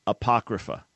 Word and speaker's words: Apocrypha: And it's pronounced Apocrypha.